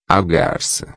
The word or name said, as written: garca